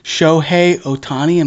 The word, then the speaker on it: Shohei Ohtani: Shohei Ohtani, did I pronounce that right? – Yes, sir.